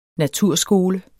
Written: naturskole

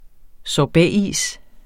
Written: sorbetis

